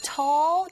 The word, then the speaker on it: tall: 이때 tall 할 때 여러분, tall, 이 발음하실 때도요. 위아래로 입을 벌려서 발음하시면 됩니다. 특히 L 사운드로 끝나야 되기 때문에 우리가 이 끝에 나는 L 사운드를 dark L 사운드라고 하는데요. 이 소리 내실 때 혀끝의 위치가 이 윗니 아 뒤에 붙여주셔야 돼요.